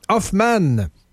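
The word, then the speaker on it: Hofman: Moi j'ai appris qu'il fallait le prononcer comme ça, Hoffman, Vous n'avez pas compris que cet être est un fucking génie.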